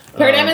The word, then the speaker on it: paradigm: Paradigm is a silent G.